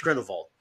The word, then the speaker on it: Grindelwald: He's German. It's Grindelwald.